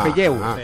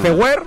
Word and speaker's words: Feuer: Pero se escribe F-E-U-E-R. – Ah. Feyeu. – Feuer. – Vale. Feuer. Feuer suena bien.